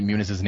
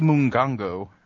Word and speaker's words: Muniz: And he keeps saying Frankie Muniz's name wrong. It's like, I'm here with Frankie Mungongo. It's like, it's Muniz.